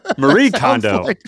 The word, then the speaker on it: Marie Kondo: I guarantee you're saying her name wrong, by the way. Like, Maria Kondo? – Marie Kondo. – That's like a Latina who's a real estate agent. Maria Kondo. Her name is Marie Kondo.